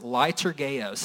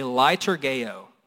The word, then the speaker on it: Leitourgeo: This is pronounced Leitourgeo. Say Leitourgeo.